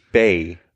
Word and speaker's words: Bae: Bäh? Bäh? Bye? – Bäh? B-A-E? – Ja, genau. – Das spricht sich Bay. Bay, dann das. Okay. Ja. Ne? Bay ist, das kann ich sagen, das dänische Wort, das dänische Wort für Kacke nebenbei.